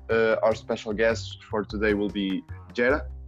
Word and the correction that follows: Jeta: Jera, uh, am I pronouncing it correctly or should we No, it is Jeta. – Jeta, okay.